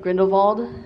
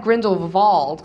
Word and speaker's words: Grindelwald: I was wondering what you guys thought about Grindelwald. – Mm-hmm. – And noticed that like the W is pronounced V as in German pronunciation.